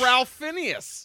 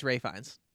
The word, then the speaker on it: Ralph Fiennes: It's Ralph Fiennes. – No, it's Ray Fiennes. – Oh, well. – But Ralph. – I know, it is spelled Ralph Fiennes, but it is Ray Fiennes.